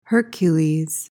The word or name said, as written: Hercules